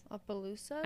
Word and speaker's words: Opelousas: So picture a fun, chaotic Friday afternoon So picture a fun I think it was where they came from, which is Opelousa or some shit. But okay. – Opelousas. I know how to say it because I fucking listen to a podcast.